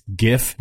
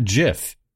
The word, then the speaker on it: GIF: It's GIF, not JIF.